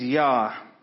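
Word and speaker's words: yare: So again, a little bit of Googling, and the Hebrew word for fear is yar, and I believe that's how you say it. That's Y-A-R-E.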